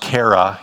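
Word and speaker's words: chara (χαρά): That X-A-P-A is actually pronounced chara in the Greek language.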